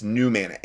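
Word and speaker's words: Numanna: Okay, New Mana, which is spelled N-U-M as in Mike, A-N-N-A. Okay, like manna from heaven, it's New Mana. N-U though, not N-E-W.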